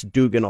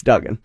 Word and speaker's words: Duggan: Like I heard Max Dugan all year. – Yeah. – It's Duggan.